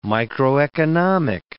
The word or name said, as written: microeconomic